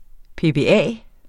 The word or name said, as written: PBA